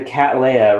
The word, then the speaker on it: Cattleya: There are certain, like the Cattleya, right? Is that, am I pronouncing it right? – You are.